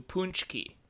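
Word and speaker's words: Pączki: Punch. Like punch, but with a weird U. Punschke.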